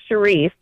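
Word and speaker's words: Chyrisse: So my name is Sharice. – Sharice? – But the way it's spelled looks like Chrissy.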